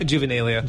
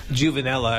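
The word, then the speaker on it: Juvenilia: How do you say that? – Juvenalia. – Juvenalia. What did I say? – Juvenella. I think it's some sort of ointment or cream.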